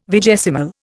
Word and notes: vigesimal: Indian

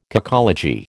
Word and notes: cacology: American English

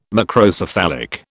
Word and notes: Macrocephalic: British English

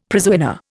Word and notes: prizewinner: Indian

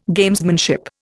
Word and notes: Gamesmanship: Indian

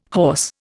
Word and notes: hoarse: Indian

